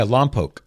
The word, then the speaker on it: Lompoc: People like Nathan in, is that pronounced Lompoc, California? – Yeah, Lompoc.